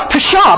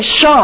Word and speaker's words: pshaw: Which we pronounce pshaw, but it's really shaw, I found out later. The P is silent.